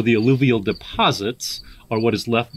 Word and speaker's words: alluvial: Is it alluvial? – Alluvial, yes.